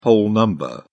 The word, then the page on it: whole number: British English